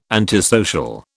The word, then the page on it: antisocial: British English